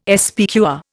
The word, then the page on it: SPQR: Indian